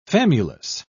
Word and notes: famulus: British English

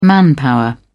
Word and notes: manpower: British English

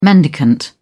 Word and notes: mendicant: British English

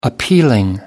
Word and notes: appealing: American English

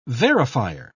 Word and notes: verifier: British English

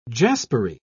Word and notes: jaspery: British English

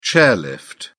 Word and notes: chairlift: British English

